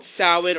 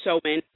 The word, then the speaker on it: Samhain: Now, it's called, like I said, Sau-in or Sow-in, and it's spelled S-A-M-H-A-I-N, and is known in Gaelic as Samhain.